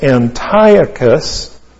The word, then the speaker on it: Antiochus: This is a prophecy of a man whose name was Antiochus Epiphanes. And Antiochus is A-N-T-I-O-C-H-U-S. A-N-T-I-O-C-H-U-S. His second name is Epiphanes. E-P-I-P-H-A-N-E-S. E-P-I-P-H. A-N-E-S.